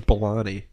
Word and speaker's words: Balani: Raj Balani. Yeah. – But it's pronounced Milani? – No, it's Balani. – Oh, okay. Yeah. I've just when you say it fast, it doesn't. You don't hear the B.